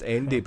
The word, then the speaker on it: Ende: Wolfgang Peterson and Michael End. – I think it's pronounced Ende, by the way. – Is it Ende? I suppose it would be if it was German. Ende. – I think it is Ende.